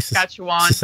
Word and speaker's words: Saskatchewan: You've mentioned that, you know, British Columbia, Saskatchewan. I hope I said that, pronounced that correctly. – Saskatchewan. – Sorry. Ontario. Yeah, Saskatchewan. All right, I'll forgive you.